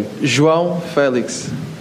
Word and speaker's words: João Felix: Could you tell us how to say your name? João Felix. Felix. Felix, yeah, and that's it.